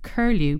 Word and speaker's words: K3RLU: This next one is Curlew, but it's spelled K3RLU. Yes, K3RLU.